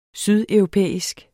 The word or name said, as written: sydeuropæisk